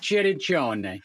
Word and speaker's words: Cirincione: Giuseppe Cirincione.